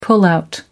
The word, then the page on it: pullout: American English